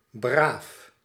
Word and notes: gewöhnlich: /ɡə.ˈvøːn.lɪç/

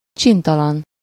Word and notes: vilain: [vi.lɛ̃]; France

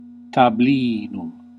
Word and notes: study: /ˈstʌd.i/

